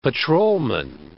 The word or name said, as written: patrolman